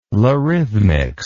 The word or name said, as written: larithmics